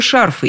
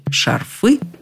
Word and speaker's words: шарфы: Но и летом, и зимой мы покупаем и носим только шарфы, именно с таким ударением. Покупать, а тем более носить шарфы, словари нам не рекомендуют категорически, так же, как и банты.